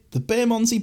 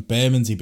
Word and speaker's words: Bermondsey: I went on the Bermondsey Beer Mile. I hadn't even heard of it. – Bermondsey, pronouncing it wrong. Bermondsey Beer Mile.